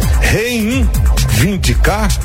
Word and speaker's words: reivindicar: Reivindicar? Não. Então você tira esse primeiro N aí também.